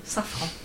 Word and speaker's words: Safran: On dit en français, Safran.